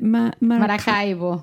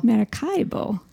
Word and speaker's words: Maracaibo: Maracaibo. – Maracaibo.